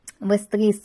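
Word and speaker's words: Vestris: Вестрис — ударение итальянское. На итальянском языке очень часто именно на последний, на второй слог ударение. Для нашего русского менталитета все-таки чаще ударение спереди, поэтому вот здесь такая немножко игра слова, и на русском нам удобнее все-таки слышать и читать Вестрис.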